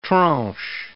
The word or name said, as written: tranche